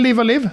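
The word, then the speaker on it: Live A Live: hur uttalar man det igen? – Live Alive? Eller Live Alive? Ja, nej, Live Alive ska det vara.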